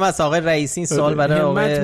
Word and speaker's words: همت: آقای مجتبی خاتمی هم یه کامنتی گذاشته بود در ادامه بحث این همت و همت، نوشته بود که اگه بخوایم درست عین حروف اختصاری رو تلفظ بکنیم باید بگیم همت، کسره زیر ه و کسره زیر میم، نه همت و همت و اینا که درست هم هست